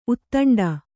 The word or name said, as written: ಉದಂಡ